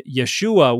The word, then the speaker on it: Yeshu: And if the Galileans didn't pronounce that glottal stop or pronounced it poorly, it would have just been Yeshu.